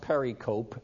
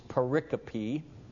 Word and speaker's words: pericope: No, it's not pronounced pericope, it's pericope, or section.